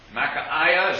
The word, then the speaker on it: Micaiah: I may not be pronouncing his name quite right. I get that I in there. Micaiah, is that how it's pronounced? – I think Micaiah is correct. – Micaiah? Well, I've heard it pronounced the other way on the recording, so it gets confusing to me. But it's easier for me to say Micaiah. So we'll call him Micaiah.